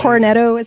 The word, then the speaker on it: Cornetto: Cornetto, is that how you say it? – Yeah.